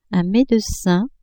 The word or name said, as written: medecin